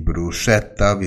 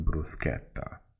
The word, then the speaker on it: bruschetta: Nicht die bruschetta, wie sie in Deutschland ausgesprochen werden, sondern S vor ein CH nach S wird im Italienischen wie ein K ausgeschrieben, also bruschetta.